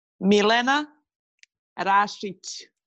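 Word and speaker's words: Milena Rašić: Milena Rasic. – Milena Rasic. – Exactly. Rasic. But they always say Rasic, Rasic, especially in France, Rasic.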